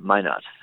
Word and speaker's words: Minot: Minot. Am I pronouncing that right? – It's called Why Not Minot.